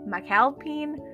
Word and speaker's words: McAlpin: Por tanto, no sé si se pronuncia McAlpine, McAlpine, McAlpine, McDonald's, no tengo ni idea yo una vez escuché el podcast de Bay, que es como mejor amiga de, de, de Lizzie McAlpin, que también es mi artista favorita, y ella decía, Lizzie McAlpin. Bueno, anyways, pero yo me quedé con que es Lizzie McAlpin, así que se va a quedar como Lizzie McAlpin.